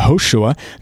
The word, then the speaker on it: Yehoshua: Yehoshua, the sound that became the J sound, the hard J sound, was at one point a Y sound.